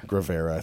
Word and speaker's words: Guevara: Guevara, I think. – It's like Gruyere cheese.